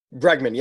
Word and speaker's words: Bregman: And I want to make sure I'm saying that right. Bregman? – Bregman, yep.